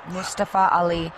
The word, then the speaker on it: Mustafa Ali: Mustafa Ali. – I'm doing fine, thank you, Dan. – I love you, Mustafa Ali.